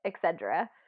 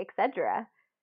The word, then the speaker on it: et cetera: I can't even say it the way you say it, excedra. But you say it nicely. I think you say, oh my gosh, see, do I say it wrong? Because I say et cetera. – There's no X in it.